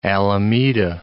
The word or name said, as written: Alameda